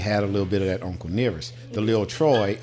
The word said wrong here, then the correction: Lefroy: I'm saying that wrong. Could you please Lefroy? – Lefroy.